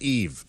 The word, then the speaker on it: Yves: Now that, because of the YV, I've only read it. Yeah. That's pronounced Eve.